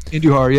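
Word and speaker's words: Andújar: Is that how you say it? Andujar? Andujar? – Andujar, yeah.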